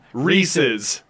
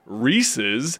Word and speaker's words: Reese's: Everyone says Reese's because it rhymes with PCs. And PCs isn't a word.